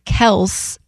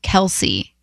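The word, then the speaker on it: Kelce: And said that we should be saying Kels instead of Kelsey.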